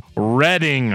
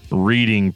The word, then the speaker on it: Reading: He comes in and says, as somebody who lives 45 minutes away, it's pronounced Reading.